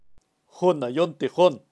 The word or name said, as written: junayon